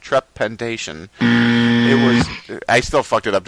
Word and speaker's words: trepanation: T-trepanation? – Spell it for me. – T-R-E-P-A-N-A-T-I-O-N. Panation. – Yeah, trepanation. – Trepanation. Okay.